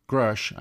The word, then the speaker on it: Grusch: The whistleblower, David Charles Grush, I believe that's how it's pronounced, it's spelled G-R-U-S-C-H,